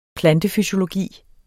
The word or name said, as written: plantefysiologi